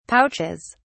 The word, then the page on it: pouches: British English